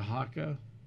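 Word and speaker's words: Hoka: Like the haka, that's how it's pronounced. – Yes, yeah. – Like the haka dance.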